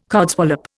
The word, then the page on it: codswallop: Indian